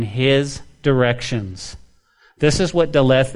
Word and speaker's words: Daleth: This is known as Daleth. Daleth, okay? And it almost sounds like death. There's a D in it, but it's Daleth. And some people say Dalet. Maybe the H is silent.